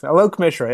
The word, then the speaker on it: Alok Mishra: Alok Mishra, yep, exactly.